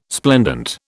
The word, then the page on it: splendent: British English